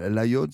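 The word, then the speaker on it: Laguiole: Euh, alors, euh, Gavin, l'Aïol ça se situe où sur la carte Alors, nous n'oublions pas que ça s'écrit L-A-G-U-I-O-L-E. J'aime bien cette prononciation.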